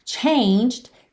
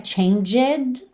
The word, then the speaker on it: changed: Changed está no passado. Lembra que a pronúncia não é changed, ok? A gente tem um ED aí, mas o E não é pronunciado. Então, the heartbreak changed me.